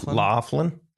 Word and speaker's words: Loughlin: Loughlin.